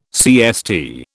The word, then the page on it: CST: British English